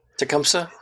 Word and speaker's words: Tecumseh: Tecumseh. – Tecumseh.